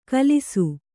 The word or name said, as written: ಖುಲಾಸು